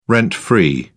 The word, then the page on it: rent-free: British English